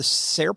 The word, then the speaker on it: SAIR: It was the capital S-A-I-R. Is it pronounced the SAIR program? – Yeah, we call it SAIR, Student Artists in Residence.